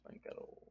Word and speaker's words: Banchero: Is it Bancaro or Banchero? – Banchero, but it's pronounced Bancaro.